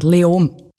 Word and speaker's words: Leoben: Spricht man es Löwen aus? Wie spricht man das überhaupt aus? – Eigentlich fast kein Konsonant. Leom.